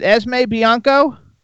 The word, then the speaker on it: Esme Bianco: Is it Esme Bianco? – That's correct, yeah, from Game of Thrones.